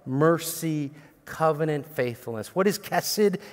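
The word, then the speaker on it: kesed (חסד): I was joking around last week because I think you pronounce it differently, but my Hebrew professor said kesed. So I was like, kesed. Doesn't matter, it's just being north and south, who knows.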